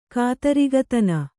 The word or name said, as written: ಕಾತರಿಗತನ